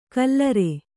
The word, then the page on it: ಕಲ್ಲರೆ: kallare